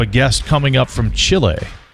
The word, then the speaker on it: Chile: She's with Team Canada in the three-on-three version of Team Canada down in Chile. And you said it correctly there.